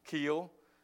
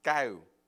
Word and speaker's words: Keo: Now, my name is spelled Touch, and the last name is K-E-O, and most people call me Touch Keel, but I got to tell you how you say it in Cambodia, okay? It's Tu Gao.